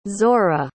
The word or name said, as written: zorah